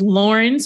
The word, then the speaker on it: Lauryn: My name is Lauryn, spelled with a Y. So if you just throw that into Google, L-A-U-R-Y-N Williams, you can find my personal website about sports and social media, et cetera.